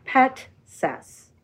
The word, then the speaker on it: Petsas: It's spelled P-E-T-S-A-S. – And it is pronounced? – Petsas. – That is exactly what I said. – You said Pestas.